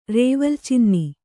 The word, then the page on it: ರೇವಲ್ಚಿನ್ನಿ: rēvalcinni; kannada